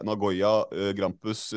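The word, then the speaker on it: Nagoya Grampus: 14 dager senere så skåra du Eh, to mål i debuten for uh, Nagoya uh, Grampus. er det uttalt riktig, for så vidt? – Det uttaler du helt riktig, ja.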